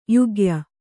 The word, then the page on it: ಯೋಗ್ಯೆ: yōgye